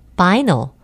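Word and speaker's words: vinyl: 비닐은 B-I-N-Y-L, 바이널, 바이널을 잘못 발음하는 경우입니다. 그래서 비닐이라 그러면 미국 사람들은 알아듣지 못합니다.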